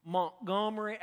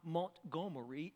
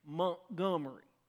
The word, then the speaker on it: Montgomery: but close to Montgomery, Alabama. That's how it's pronounced, just in case you're wondering. It's not Montgomery, it's Montgomery, okay? Just want to make sure we get that clear.